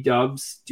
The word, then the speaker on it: Doubs: I mean, like, and again, am I not pronouncing his last name right? – Because I think that's how you say it, actually. – Dubs, Romeo Dubs. Dubs.